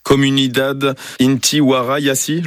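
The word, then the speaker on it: Comunidad Inti Wara Yassi: Comunidad Intihuara Yasi, je le prononce bien ? – Exactement, c'est ça.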